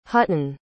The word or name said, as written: Hutton